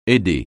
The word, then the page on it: aider: français